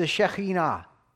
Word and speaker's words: Shekhinah: And that divine presence is referred to as the Shekhinah. That's how you pronounce it.